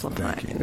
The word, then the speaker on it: Lapine: I think you're right. It's Lapine. – It's Lapine? – It's Lapine. Yeah. – And I said Lapine? – You did indeed. – Okay. Well, the emphasis on the wrong syllable, as they say.